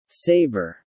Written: saber

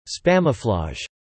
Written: spamouflage

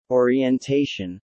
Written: orientation

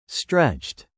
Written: stretched

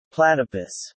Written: Platypus

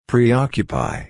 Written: preoccupy

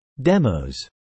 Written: demos

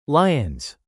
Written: lions

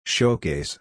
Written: showcase

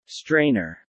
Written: strainer

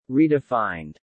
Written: redefined

